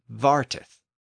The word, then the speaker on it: farteth: So if this song was composed in that region, it would have been common to pronounce fart as vart, and farteth as varteth.